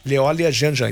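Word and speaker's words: Léolia Jeanjean: Leolia Janjan. – É Leolia. – Leolia Janjan, exatamente. Ou seja, a pronúncia está correta.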